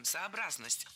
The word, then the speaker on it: сообразность: Но зато в русском языке есть слово «сообразность». Именно с таким ударением – «сообразность».